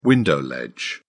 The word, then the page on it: window ledge: British English